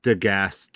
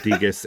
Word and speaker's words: Degas: I think you just need to ask yourself anytime you're worried, just say, what would Edward Degas do? – Degas. No, that is not correct. Degas. Degas. – Edward Degas. Yeah. I'm sorry. Mispronounced that.